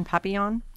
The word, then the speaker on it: papillon: P-A-P-I-L-L-O-N, papillon?